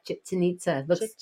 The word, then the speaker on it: Chichen Itza: Yeah, Chichen Itza. Think of chicken and nitzas.